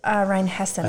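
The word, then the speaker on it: Rheinhessen: Is that you pronounce it Rhine-Hessen or Rhine-Heisen? – Rhine-Hessen, I think. Rhine-Hessen. I think you're right, yeah.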